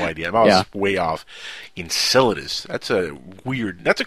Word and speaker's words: Enceladus: So don't feel bad, but it's pronounced Enceladus.